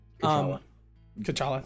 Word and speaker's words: T'Challa: T'Challa, thank you.